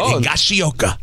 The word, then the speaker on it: Higashioka: That's how they say it. Higashioka.